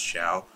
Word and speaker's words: Zhao: So I'm going with Philip Zhao. Or Shao. I think it's pronounced Shao.